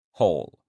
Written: whole

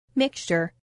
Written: mixture

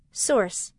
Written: source

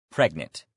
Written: pregnant

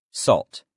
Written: salt

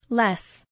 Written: less

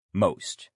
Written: most

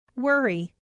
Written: worry

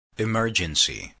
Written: emergency

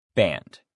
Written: band

